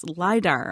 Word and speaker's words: LIDAR: One cool thing coming up has to do with a new way to discover lost cities, LIDAR. Am I pronouncing that right? – Yeah, actually.